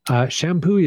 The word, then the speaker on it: Champoeg: So pro tip for those who are listening to this podcast, Shampooey is not spelled the way you would think it is. – No, it's not. – It's not. It's Shampoeg. – Yeah. – Yeah. – Champoeg is how it's spelled.